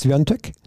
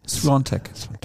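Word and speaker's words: Świątek: Swiatek? Swiatek. Ganz merkwürdige Aussprache. – Ja, das A äh, hat unten eigentlich, also hat er jetzt nicht hier drin, aber das A hat unten so einen Strich.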